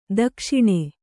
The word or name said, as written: ದಕ್ಷಿಣ